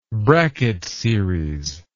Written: Brackett series

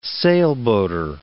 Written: sailboater

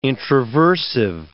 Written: introversive

